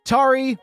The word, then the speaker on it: Tari: Tari, rhymes with Jabari, Welshlander, Garrett G, aka One Big Curd, Eric M, Mr. D, Tommy K.